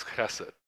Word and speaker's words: chesed: And that's God's chesed. You got to say it like you're trying to spit at someone. It's from the back of your throat.